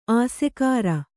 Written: ಆಸೆಕಾರ

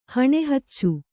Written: ಹಣೆಹಚ್ಚು

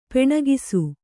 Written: ಪೆಣ್ಗೂಸು

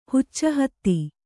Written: ಹುಚ್ಚುಹತ್ತಿ

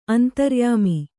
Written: ಅಂತರ್ಯಾಮಿ

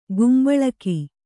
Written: ಗುಂಬಳಕಿ

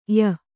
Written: ಯ

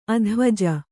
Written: ಅದ್ವಿಜ

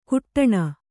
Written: ಕುಟ್ಟಣ

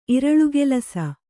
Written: ಇರಳುಗೆಲಸ

